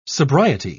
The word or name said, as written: sobriety